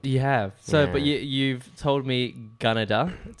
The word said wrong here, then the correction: Gunnedah: Close. It's more with an R, like Gunnedah. – Gunnedah, like Canada.